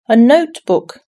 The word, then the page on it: notebook: British English